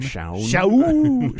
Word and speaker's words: Shaun: Sean Manning, S-H-A-U-N. You could probably hear that I didn't. Did I pronounce it right? Sean. – Sean.